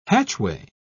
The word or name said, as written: hatchway